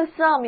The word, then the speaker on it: some: 接下来的几个单词都和 some 有关系。首先来看看 some SOME，虽然是开音节单词，但是字母 O 要读作啊。 Some，做代词讲的时候表示一些或者若干。